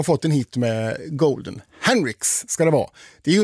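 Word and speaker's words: HUNTR/X: Jag sa ju att det här var Golden med Hunter X. Nej, så ska man inte uttala det där namnet, har jag lärt mig nu. Utan det här är ju, det ska vara Henrix ska det uttalas.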